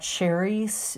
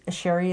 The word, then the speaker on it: Cheri: But you can email me at Sherry. Sherry is spelled C-H-E-R-I.